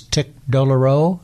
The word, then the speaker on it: tic douloureux: And as I recall from medical school, another name for this was tic douloureux. Did I pronounce that correctly? That's right.